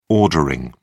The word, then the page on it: ordering: British English